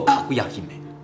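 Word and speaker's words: かぐや姫: 由于他呢是在竹子里发现，而且闪闪发光，所以这个大祭司啊就给这个小女孩取名为叫家居业比卖命。读作卡古亚伊美哦，最后这个命啊，就是神称嘛，就神的名字后边都有个命嘛。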